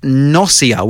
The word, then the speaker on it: Gnosia: Uh, Petit Depoto have announced Nausea with a silent G or Gnosia for the Nintendo Switch, a creepy RPG visual novel coming out early 2021.